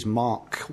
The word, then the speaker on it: Mach: Is that how you say it? Mach or Mac? I never know. – Well, Mac, I think. – M-A-C-H, yeah. – People pronounce it both ways. In different ways.